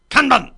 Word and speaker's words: Kanban: You know, all right, what's the correct pronunciation, please? – Kanban! – Thank you.